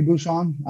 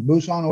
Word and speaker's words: Bousson: Bousson always kind of gets butchered. So my nickname turned into Boss. People just took out the U out of my name. Boss is a lot easier to pronounce than Boos.